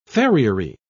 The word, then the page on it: farriery: British English